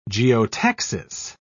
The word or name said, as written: geotaxis